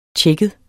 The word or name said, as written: tjekket